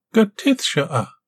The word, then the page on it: Gateth-shoa: Galindri